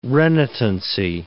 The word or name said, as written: renitency